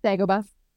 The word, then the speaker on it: Dagobah: He hit out on Dagobah. Is that how you say it? – Dagobah.